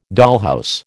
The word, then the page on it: dollhouse: American English